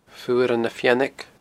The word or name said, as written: Fuaran na Feannaig